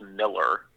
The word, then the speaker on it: Millar: It's Miles Miller, even though it's spelled M-I-L-L-A-R.